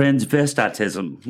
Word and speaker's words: transvestitism: Number seven, the Bible does specifically address homosexuality and transvestism. Did I say that right? – Transvestitism. – Transvestitism. – That's a big word. – Big word, transvestitism.